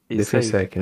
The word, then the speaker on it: Defensec: Como é que é difícil falar? Defensec Blog. É assim que se pronuncia. – Defensec. – Defensec, né?